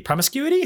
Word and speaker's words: promiscuity: And you could take that, you know, and that's kind of like about STDs, about teenage promiscuity. Promiscuity? How do you say that? – Promiscuity. – There you go.